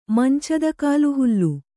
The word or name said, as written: ಮಂಚದಕಾಲುಹುಲ್ಲು